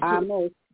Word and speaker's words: Amos: Amos. Two syllables. There you go. – Amos.